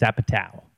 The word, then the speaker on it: Apatow: Is it Apatow or Apatow? – It's Apatow. – Apatow. I said it right the first time.